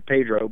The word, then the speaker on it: Pedro: P-E-D-R-O, spelled Pedro, but pronounced Pedro.